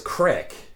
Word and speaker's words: crick: Oh, a crick is crick. A little different than a creek. A crick is something that's also water, but it's not as big as a creek. It's smaller. A crick. And it's spelled C-R-I-C-K, not C-R-E-E-K.